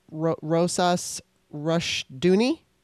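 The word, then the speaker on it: Rousas Rushdoony: It was Rosas Rushdoony. – Did it really well. – Oh. – Yeah, perfect.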